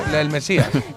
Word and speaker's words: El Mesías: Hoy una serie que está muy de moda, que ya está en plataformas y que se llama La Masía. ¿Lo he dicho bien? No. El Mesías.